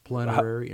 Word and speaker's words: plenary: Plenary indulgence. – How do you say it? – Okay, you're making it shorten it. Plenary.